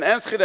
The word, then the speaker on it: Enschede: En Enschede.